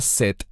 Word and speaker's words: Shet: Normalmente Shet se pronuncia Set en español.